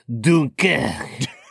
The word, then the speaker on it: Dunkirk: I mean, I say it as the French do. Dunkirk.